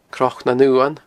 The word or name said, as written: Cnoc nan Uan